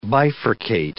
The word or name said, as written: bifurcate